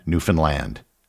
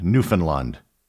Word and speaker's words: Newfoundland: The key was the simple rhyme, "Understand Newfoundland." It is not pronounced as it would be in the rhyme, "Unfund Newfoundland."